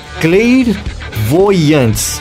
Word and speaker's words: Clairvoyants: Clair Voyantes. É assim que se pronuncia.